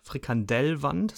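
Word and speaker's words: Frikandel: Man konnte ausschließlich in der Halle da an der, an der Pommesbude oder an der Frikandelwand, Frikandellwand, so wird das eigentlich ausgesprochen, was bekommen.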